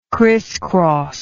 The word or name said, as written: christcross